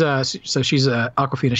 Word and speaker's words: Awkwafina: Even though I know she spells it differently, I'm like, surely I'm not pronouncing it the same way as him. But there you go. She's Awkwafina.